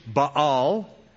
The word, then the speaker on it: Baal: They forsook the Lord and served Baal, or the correct pronunciation, Baal, and the Ashtoreths.